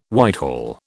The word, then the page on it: Whitehall: British English